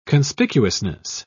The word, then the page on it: conspicuousness: British English